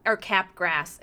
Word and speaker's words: Capgras: And its name is Cat Gra, even though it looks like cat grass, so it's Cap Grass, C-A-P-G-R-A-S, but he's like, but it's pronounced Cap Gra.